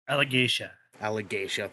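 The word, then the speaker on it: Alagaësia: Aragorn and Saphira set out to defeat Galbatorix and free the land of Alagasia, I believe is how it's pronounced. Fact check. – Allegasia, thank you, from his tyranny.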